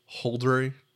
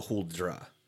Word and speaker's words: Huldra: Next thing we'll run into, I don't know if you got any notes about it, is the Huldry. – The Hul, yeah, it's got a couple of different pronunciations. It's the Huldr or the Huldra, and it is it's Scandinavian folklore, and she is a seductive forest creature.